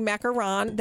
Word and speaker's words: macaron: So we're not trying to be fancy when we say macaron. That is, we've learned, Kirsten has taught us that that's actually how you say it.